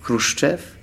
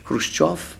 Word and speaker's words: Khrushchev: Et lorsque la fin du stalinisme est tout d'un coup arrivée avec le, le fameux congrès de euh, Khrushchev, ou Khrushchev, comme vous dites ici, ou Khrushchev, en fait ça se prononce Khrushchev, Khrushchev, euh,